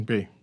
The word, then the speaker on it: bae: I don't know why youths choose to pronounce it sound or so, but it's B-A-E. Bay, B-A-E. – Mm-hmm, Bay.